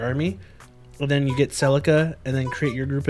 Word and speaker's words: Celica: And the girl's name was Celica, because I said it wrong last time.